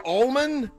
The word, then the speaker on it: almond: Is it almond or almond? – Almond. – It's almond. – Almond. – The L is silent.